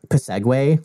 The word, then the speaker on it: Psegway: Except it would be like Psegway, like it would be spelled like psychic. There would be a silent P at the beginning.